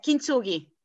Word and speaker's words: Kintsugi: Kintsugi? – Yes, Kintsugi.